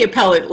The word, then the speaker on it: appellate: You know, even in the appellate level, I can't even believe I pronounced that wrong. – appellate.